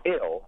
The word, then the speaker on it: evil: And one of the things I came across is that the V sound in the middle of the word would typically be dropped, and you see that expressed like, what error, E apostrophe ER, or devil would be pronounced dill, which led me to the word evil, which would then be pronounced ill,